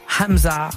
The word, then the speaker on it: Hamza: Emza. Hamza, Gazo et Guy de Besbar.